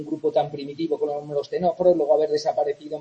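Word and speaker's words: ctenóforos: Veíamos que las primeras neuronas aparecían, acordaros, en los dos clados, las dos ramas de ese árbol evolutivo que contienen, cuyo nombre tiene una C que no se pronuncia, ¿vale? Esa es la clave para acordarnos. Los tenóforos y los nidarios, que acordaos, antiguamente estaban unidos en un grupo que se llamaba, que conocíamos como celenterios.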